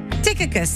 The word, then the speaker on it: Tychicus: And his name, different people say his name differently, but it's actually Tychicus. Tychicus, some people say Tychicus, is T-Y-C-H-I-C-U-S, but it's Tychicus.